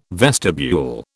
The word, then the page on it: vestibule: British English